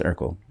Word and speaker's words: Urkel: So I knew how to pronounce Urkel. And it is Urkel. Like the... Did I do that?